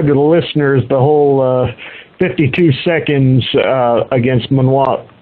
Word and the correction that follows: Mwande: I'm sorry, pronouncing the name Mwande. – Wrong. Mwande, yes.